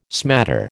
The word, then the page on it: smatter: American English